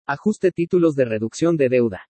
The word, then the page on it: AJUSTE TÍTULOS DE REDUCCION DE DEUDA: Spanish